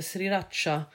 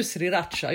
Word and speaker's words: sriracha: Du uttalar det annorlunda. – Sriracha? Men det är för att jag säger R och du säger R. – Nej, sriracha säger du. – Ja, sriracha. – Och jag säger sriracha.